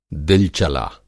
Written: दिलचला

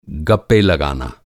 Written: गप्प

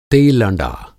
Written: तेलँडा

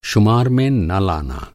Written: शुमार

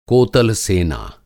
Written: कोतल-सेना

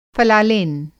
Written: फ़लालेन

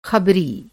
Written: ख़बरी

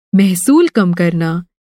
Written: महसूल

